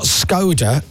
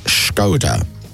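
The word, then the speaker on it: Škoda: So, we get told it's not Skoda, it's actually pronounced Skoda. So we're like, okay. Yeah, so basically, you've been saying it wrong for years. It's Skoda, not Skoda.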